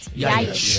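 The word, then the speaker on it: Ljajić: And then there's Aston Villa, who are set to sign Adam Ljajic. Is that how you say it? – Ljajic. Yeah.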